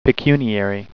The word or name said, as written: pecuniary